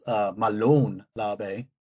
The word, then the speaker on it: Molon Labe: It's technically pronounced Malon Labe. People say, oh, yeah, I got that one all wrong. – It's all right. People just swap the vowel sounds around, but it's okay.